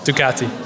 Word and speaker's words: Ducati: Lead designer of the Ducati Street Fighter V2, and I'm working on my pronunciation, Ducati. – Ducati, yeah.